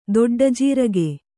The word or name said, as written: ದೊಡ್ಡಜೀರಗೆ